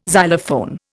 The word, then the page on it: xylophone: Indian